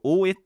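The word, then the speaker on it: 大枝: これもちょっと大江って読むんですけどこれ大きいに枝って書いて大江ですねか、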